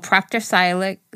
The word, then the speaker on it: Proctor Silex: And this is a little Proctor Silex. That's how you pronounce that.